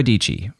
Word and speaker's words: Dietschy: Her name is Sarah Dietschy, rhymes with peachy, and she has more than 800,000 subscribers here on YouTube.